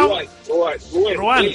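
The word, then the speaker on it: Rouen: En Rual. – En ¿cómo se llama? Ruén. ¿Y eso está? – Ruén. Sí, ay, lo pronuncia bien.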